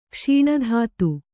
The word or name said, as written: ಕ್ಷೀಣಧಾತು